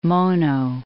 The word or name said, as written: Mono Lake